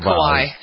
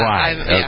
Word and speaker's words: Kauai: It's on Kauai. And I was wondering if I'm pronouncing that right. There's so many vowels. One consonant. – I've always said Kauai. Yeah. – Kauai.